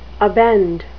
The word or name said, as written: abend